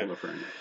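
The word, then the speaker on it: Lafrenière: How do they say it? Alexis Lafreniere or something? Is that how they say it? – Yeah, Lafreniere. – Well, it depends how French you are.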